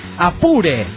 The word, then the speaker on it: Apure: Apure se pronuncia Apure, valga el acento.